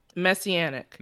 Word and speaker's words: messianic: Messianic. – Messianic. Like you have an anic, but it's it. – A bit of a mess.